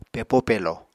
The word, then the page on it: pepó-pelò: Apatani